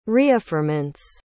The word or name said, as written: reaffirmance